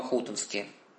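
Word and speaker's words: Хутынский: Всю новгородскую землю в свое время осветил своей молитвой, своим подвигом преподобный Варлаам Хутонский. Именно такое делают ударение в его имени.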